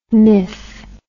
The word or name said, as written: mif